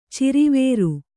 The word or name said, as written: ಚಿರಿವೇರು